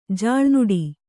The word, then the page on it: ಜಲನಾಡು: Kannada